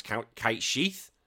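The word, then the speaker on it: Cait Sith: And then recently, as of like the past few months, I've only found out it's actually meant, well, originally it's meant to pronounce Kite Sheath. I was like, fuck, what? – But honestly, it's spelled S-I-T-H.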